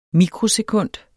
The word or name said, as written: mikrosekund